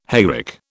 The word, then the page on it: hayrick: British English